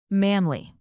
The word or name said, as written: manly